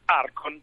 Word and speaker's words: Archon: Allora do il benvenuto che è il cofondatore di Archon, Arcon, non so come si pronuncia. Buongiorno, benvenuto. – Buongiorno, buongiorno a tutti, grazie. – ARCH, quindi come si pronuncia la vostra azienda? – Arcon. – Arcon.